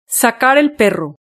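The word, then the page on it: sacar el perro: Spain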